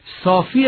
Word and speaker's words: صافی: صافی اسم فاعله، مثل رازی و قاضی هم تلفظ باید بشه.